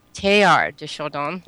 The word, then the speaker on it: Teilhard de Chardin: Well, you didn't do too badly. It's Teilhard. – Teilhard de Chardin. – De Chardin. – Chardin. Okay. – Yeah, very good.